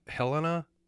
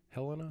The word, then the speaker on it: Helena: Is it Helena, Montana? Is capital? Helena? – Yep.